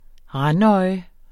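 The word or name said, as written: RANDØJE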